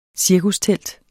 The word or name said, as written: cirkustelt